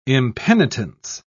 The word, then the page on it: impenitence: British English